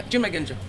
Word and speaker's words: Junmai Ginjo: Yeah, Junmai Ginjo.